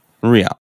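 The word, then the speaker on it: Rían: Rian, okay.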